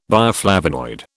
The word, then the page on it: bioflavonoid: British English